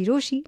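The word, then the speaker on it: Rochie: रोशी नाम की स्पेलिंग है RO CHIE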